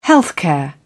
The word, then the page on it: healthcare: British English